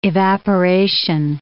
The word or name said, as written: evaporation